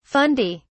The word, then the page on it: fundi: British English